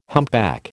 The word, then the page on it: humpback: American English